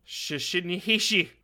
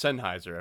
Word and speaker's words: Sennheiser: I was going to be like, ready for what I was going to say if I were to pronounce it out? Shishinihishi. – Really? Okay. Well, it's Sennheiser, everybody.